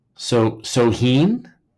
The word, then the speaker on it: Samhain: So this is like the Druids were like their priest, and they had Samhain. That's their holiday, and it's pronounced Soheen.